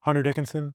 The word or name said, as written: hunter DICKINSON